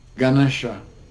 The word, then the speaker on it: Ganesha: Ganesha.